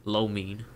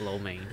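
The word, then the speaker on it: lo mein: In Chinese, it means Lo Mean, by the way. That's how you actually pronounce it. – Yeah. – But, you know, Western people call it Lo Main.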